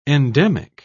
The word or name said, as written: endemic